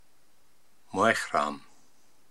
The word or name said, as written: Moehram